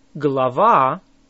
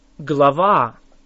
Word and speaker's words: глава: How do you say a chapter? Глава. Глава. Say ва. Лава. Глава. It starts with a г or g sound. Say it again.